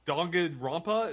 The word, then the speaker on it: Danganronpa: Okay, yeah, Danganronpa.